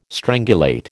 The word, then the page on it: strangulate: American English